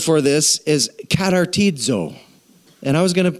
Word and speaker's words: katartizo: So you just have to imagine what the word katartizo looks like in your mind. Spelled with a K-A-T-A-R-T-I-Z-O.